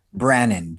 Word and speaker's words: Brannon: So do you say your name Brannon? – Brannon, B-R-A-N-N-O-N, yeah.